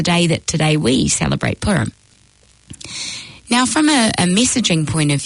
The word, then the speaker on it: Purim: So with an English pronunciation, we'd say Purim. With the Hebrew pronunciation, we'd say Purim.